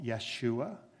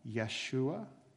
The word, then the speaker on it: Yeshua: Jesus himself was probably called Yeshua, probably not a J sound in Hebrew or in Aramaic, the language that Jesus spoke. So he's probably called Yeshua.